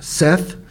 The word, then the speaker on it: saith: And again, Isaiah saith, the word saith, or Seth, is really how you pronounce it.